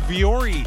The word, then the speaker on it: Vuori: Nike, I just learned how to say it the right way. It is Vuori.